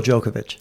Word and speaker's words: Djokovic: Listen, no matter what you say, brother, you just said it right. He's still Djokovic.